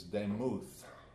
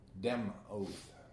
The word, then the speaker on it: demuth: A likeness is demuth. Demuth. D-E-M. The pronunciation is D-E-M-O-U-T-H. Demuth.